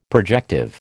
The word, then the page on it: projective: American English